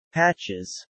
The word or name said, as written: patches